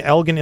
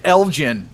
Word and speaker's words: Elgin: I don't mean to pick on you, but it's pronounced Elgin.